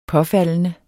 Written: påfaldende

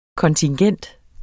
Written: kontingent

